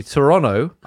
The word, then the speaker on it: Toronto: We do, in fact, pronounce the city Toronto.